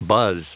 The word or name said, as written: buzz